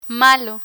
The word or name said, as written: malo